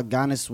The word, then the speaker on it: Giannis: Going in about how Giannis wouldn't be shit. – Giannis, not Giannis. – Giannis. – The G is silent.